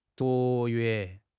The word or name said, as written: 卓越